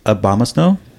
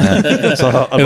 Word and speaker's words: Abomasnow: Okay, how do you say it? – I think you said it right. – Okay, cool. – So Abomasnow. It was so close. It was right.